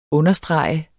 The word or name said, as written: understrege